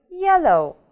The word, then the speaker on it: yellow: По-английски это будет yellow. Обращаем внимание на транскрипцию, да? Ударение падает на первый слог. Помним, что эта палочка обозначает у нас ударение. Yellow. Yellow. Желтый.